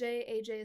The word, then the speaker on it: AyJay: AJ is spelled A-Y-J-A-Y because I am that's actually probably the most basic thing I've ever done.